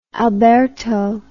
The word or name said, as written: alberto